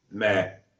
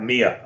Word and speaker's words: Mia: Meh. – Yeah, which is not pronounced like that. Mia, I'm sure it's pronounced Mia.